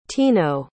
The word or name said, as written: tino